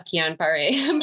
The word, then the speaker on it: Guillain-Barré: Great pronunciation of Guillain-Barré, by the way.